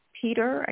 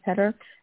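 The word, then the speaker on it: Peder Helland: And this particular arrangement is by Peter, I guess that's how you say it, P-E-D-E-R, maybe Peter, the Helland, H-E-L-L-A-N-D.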